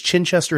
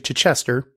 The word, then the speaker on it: Chichester: I've mispronounced that for a long time. I thought it was Chichester. It's actually Chichester.